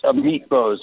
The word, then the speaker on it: Amit Bose: And I guess the Senate just confirmed, is his name Amit Bose? Am I pronouncing that correctly? – It's Amit Bose, yes.